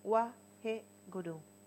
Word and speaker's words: Wahe Guru: Son 16 repeticiones, la música te va a guiar, de el mantra Wahe Guru. Este mantra significa la maravilla que te lleva de la oscuridad hacia la luz. Y lo que vas a hacer es separarlo en dos sílabas, Wahe Guru, completo.